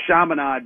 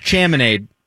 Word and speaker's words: Chaminade: That was not more impressive than Chaminade beating Ralph Sampson in 1982 or 3, whatever it was. It is not. – It's pronounced Chaminade.